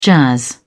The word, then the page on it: jazz: British English